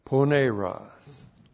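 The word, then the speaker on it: Poneros (πονηρός): You're being protected from poneros. P-O-N long E-R-O-S. Poneros.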